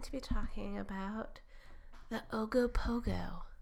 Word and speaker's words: Ogopogo: The Ogopogo. – I love it so much. Yeah. Ogopogo.